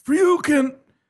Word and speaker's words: Freuchen: You'd want to say Freuchen because his last name is spelled F-R-E-U-C-H-E-N. – But it's more like Freuchen.